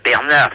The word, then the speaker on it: Bernard: Ben, Bernard, si, c'est mon prénom, mais ça s'écrit pas comme vous me le prononcez. Ça s'écrit B-E-R-N-A-R-D.